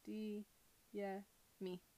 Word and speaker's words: Adeyemi: and it's called Children of Blood and Bone by Tommy Adeyemi, I think is how you say it. Adeyemi.